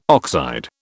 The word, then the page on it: oxide: British English